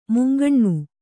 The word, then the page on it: ಮುಂಕಣ್ಣು: munkaṇṇu